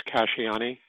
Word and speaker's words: Casciani: The name is Casciani.